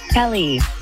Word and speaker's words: Kelly: Kelly.